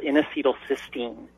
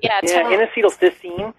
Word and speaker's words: N-acetylcysteine: There's been some evidence for people who just want any type of medicine, but a supplement, an amino acid called N-acetylcysteine, and some.